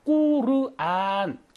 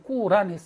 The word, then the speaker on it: 꾸르안: 왜냐하면 꾸르안 길게 읽어요. 꾸르안으로 읽어야 되거든요. 근데 꾸르안으로 읽는 게좀 어려우니까 그냥 꾸란 했습니다. 그래서 꾸란은 코란과 꾸르안 사이쯤에서 읽는 약간 뭐 중간 정도의 발음이라고 보겠습니다.